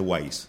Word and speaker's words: Weyes: Claro, se escribe Weyes. Claro. Blood, de sangre, pero sí sé Wise.